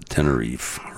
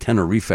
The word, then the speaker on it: Tenerife: The other thing that's haunting about this book, Collision On, as John and I both pronounce it now, Tenerife, right? Is that good? I like Tenerife.